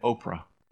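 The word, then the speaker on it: Ophrah: Now the angel of the Lord came and sat underneath the terebinth at Oprah, which is how this word is pronounced.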